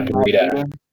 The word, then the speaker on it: Breida: And they got, I don't know how to pronounce his name, Breida? Breida? I don't know. – Yeah, Matt Breida.